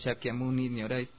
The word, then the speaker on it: Shakyamuni Nyorai: Pero ahí en sí, cómo pronuncian los japoneses es Shakyamuni Nyorai.